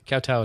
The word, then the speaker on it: kowtow: And I don't know if I'm pronouncing that right, but I looked it up, and it means bowing down and worshiping. – Kowtow is right.